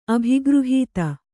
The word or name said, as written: ಅಭಿಗೃಹೀತ